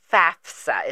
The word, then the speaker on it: FAFSA: You know, because it's pronounced FAFSA. It's F-A-F-S-A. – Right. – But a lot of people say FAFSA. – Right. – F-A-S-F-A.